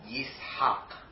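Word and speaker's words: Yishak (יצחק): Yeah, and so the word, you know, Yitzhak in Hebrew should be pronounced Yishak.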